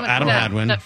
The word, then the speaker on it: Hadwin: Adam Hadwin. Is that how you say it? – Yeah, Adam Hadwin.